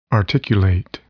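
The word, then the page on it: articulate: verb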